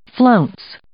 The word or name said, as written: flounce